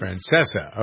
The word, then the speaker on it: Francesa: Is that how you pronounce his name, Francesca or Francesca? – It's pronounced Francesa. – Francesa, okay.